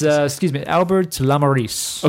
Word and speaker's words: Albert Lamorisse: It's, Albert Lamorisse. – Okay. – I believe it's Albert Lamorisse.